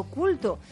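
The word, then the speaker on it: Kulto: Efectivamente, calle Ibiza número 4, Culto con K, pero Culto. También diríamos que podemos utilizar la acepción con C, porque en el fondo lo que tienen ellos es un culto por la gastronomía en general,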